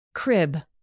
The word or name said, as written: crib